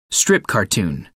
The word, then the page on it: strip cartoon: American English